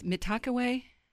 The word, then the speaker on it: Mitakuye: The other one, Mitakuye, and it's Mitakuye. Yeah, everybody tells me to pronounce this different.